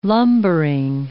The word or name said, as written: lumbering